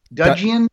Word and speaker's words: Dudgeon: And I got to be honest, he released two albums in the 80s that he produced with his 70s producer, Gus Dudgeon. – Dudgeon, right? I think that's how you say it.